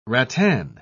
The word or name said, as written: rattan